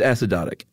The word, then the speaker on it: acidotic: It's acidotic. Oh, it is. Yeah, I actually probably would have made it a long O. – Yeah, no long O apparently.